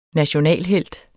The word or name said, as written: nationalhelt